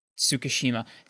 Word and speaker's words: Tsukishima: Like, to get the same reaction out of Ichigo that Tsukimichi... how do you say it again? Sorry. Tsukishima.